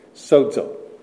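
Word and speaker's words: sozo (σώζω): The Greek word translated save there is sozo. We would spell it, if you want to spell Greek words in English letters, S-O-D-Z-O, sozo.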